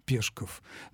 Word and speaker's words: Пешков: Да, настоящая фамилия Максима Горького была Пешков, но исключить произношение этой фамилии, если она принадлежит другому человеку с ударением на втором слоге, невозможно. Может быть и так, и так.